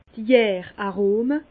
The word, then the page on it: d' Jär-Arome: Alsatian; Bas-Rhin; Herrlisheim